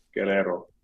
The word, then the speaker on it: Guerrero: But yeah, as I was telling Evol, her family's from Guerrero.